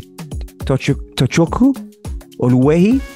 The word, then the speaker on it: Tochukwu Oluehi: The easy way to say it is Touchi, but I want to say Tochukwu Oluehi.